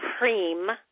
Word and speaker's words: Hrim: So her mantra is Hrim, H-R-I-I-M. It's pronounced H-R-E-E-M, Hrim.